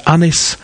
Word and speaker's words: Ynys: This is Welsh singer-musician Dylan Hughes from Aberystwyth and his band known as Unys, which is spelt Y-N-Y-S.